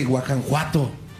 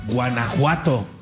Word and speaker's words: Guanajuato: No se dice Guanajuato, se dice Guanajuato.